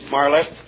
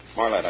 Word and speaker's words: Marlette: Is that pronounced right, gentlemen? Marlette? Marlette, I think it is.